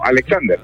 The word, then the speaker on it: Alexander: Braham Alexander. Se escribe raro. Se escribe B-D-A-J-A-N. – Brahan, así se escribe, ok. Alejandro León Argueta. – No, Alexander.